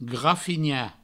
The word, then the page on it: grafinhar: grafigna; Provençal